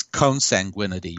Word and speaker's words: consanguinity: I was saying consanguinity, and I wasn't pronouncing the N.